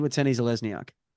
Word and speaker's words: Watene-Zalesniak: And remember it's not like he had a mid-career pronunciation change. – No, it's not like a far logo situation. – Yeah, it's always been Watene-Zalesniak.